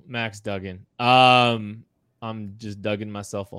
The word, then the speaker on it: Duggan: Max Duggan, I'm sorry, I, I, you said it right. I said it wrong earlier.